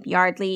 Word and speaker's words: Yeardley: And it was sort of a, a little Easter egg, like this is also how you pronounce my name, Yardley, even though it's spelled Yeardley. Just blame my dad.